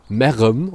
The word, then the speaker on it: Merrem: Also erstmal wird es auf Kölsch wieder so richtig geil ausgesprochen. Merrem, oder? Also es wird M-E-R. R-E-M geschrieben dann.